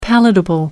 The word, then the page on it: palatable: American English